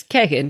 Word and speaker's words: Keegan: So this guy, Kagan, whose name is spelled Keegan, but it's Kagan because it's Keg and Klein.